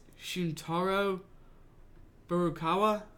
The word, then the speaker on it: Shuntaro Furukawa: And the man who's replacing him is Shuntaro Furukawa.